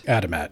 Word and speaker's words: Adamat: It starts out with a detective named, is it Adamat? – Adamat, yeah. – Is that how you'd pronounce that? Okay.